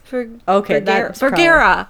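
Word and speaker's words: Bergara: I'm Bergerera? – Bergera. – It's Bergera. Okay.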